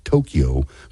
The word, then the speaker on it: Tokyo: Some people pronounce it Tokyo, but I think it's properly pronounced Tokyo.